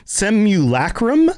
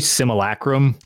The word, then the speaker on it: simulacrum: So the section that we get in Elder Scrolls Online is based around this idea of the Seven Secrets and the Simulacrum, I think is the pronunciation. – Yeah, I always say Simulacrum. – Simulacrum. – You kind of don't pronounce the U is what Simulacrum.